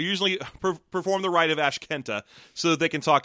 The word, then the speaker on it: Ashkente: Because I'm sure I'm pronouncing that wrong. I've always felt like there's probably a joke in that word that I'm not seeing. – It's Ashkenta. – Ashkenta? – Yeah, for Ask Enter.